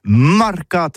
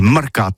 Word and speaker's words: marcat: Eh bien, d'ici à Toutis, mais le mercat de l'Uni ou le mercat des rebelles, mais en gascogne toulousain, c'est prononcé sur les marcat, avec un A marcat. En gascogne toulousaine, bah, du côté, par exemple, de l'île des Baches, c'est l'île Jourdain, pour parler du marché, vous prononcez les marcat.